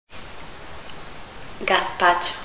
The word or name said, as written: gazpacho